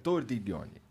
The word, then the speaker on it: Tordiglione: Como é que fala teu nome? Tordiglione, perfeito. Tordiglione, tá bom.